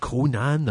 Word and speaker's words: Conan: Conan the Barbarian. – That's perfect. That's exactly it. It's the first time you've ever pronounced it correctly.